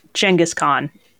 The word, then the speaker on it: Genghis Khan: Genghis Khan, actually, is the correct pronunciation, but I can't really bring myself to say it.